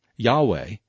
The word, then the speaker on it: Yahweh: It is the letters of the Tetragrammaton, Latinized into J-H-V-H, with vowels inserted. Yahweh, or Yehovah, is far more likely to be the correct pronunciation.